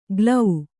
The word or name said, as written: ಗ್ಲೌ